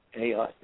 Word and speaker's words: Ayotte: Is that how you pronounce the name, yeah. – Ayotte? – Yeah, Ayotte, yeah.